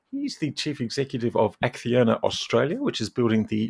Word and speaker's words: Acciona: Yes, the accent and the pronunciation was perfect. It's Acciona.